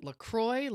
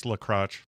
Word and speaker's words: LaCroix: Is it LaCroix? LaCroix? – No, it's pronounced LaCroix.